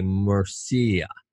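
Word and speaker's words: Murcia: You know, that it's the University of, I can't pronounce it, I say Mercy, but basically it's M-U-R-C-I-A, Mercia. Mercia.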